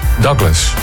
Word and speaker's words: Douglas: Een Douglas MD-88, zeg ik dat goed? – Douglas. – Douglas. Ja. En is het dan ook de MD-88? – Ja, heel goed.